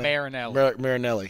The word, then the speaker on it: Marinelli: Marinelli.